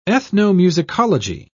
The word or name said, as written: ethnomusicology